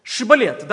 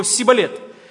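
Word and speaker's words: шибболет: Шибалет, да, то есть вот ефремляне не смогли бы произнести это слово без акцента. Они бы произносили его сибалет.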